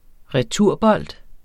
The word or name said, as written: returbold